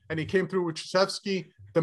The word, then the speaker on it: Krzyzewski: Coach Krzyzewski.